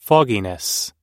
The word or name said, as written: fogginess